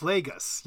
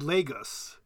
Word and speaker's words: Lagos: It's Lagos, yes. But I think you can say, in the Foreign Service, I always said Lagos, actually. I didn't pronounce it correctly either.